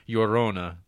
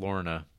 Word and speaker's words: Llorona: The double L makes a Y sound in Spanish, as found in quesadilla. – Quesadilla? – Oh, there you see. Thank you, RJ. So, Llorona would be pronounced closer, Llorona, rather than Lorna.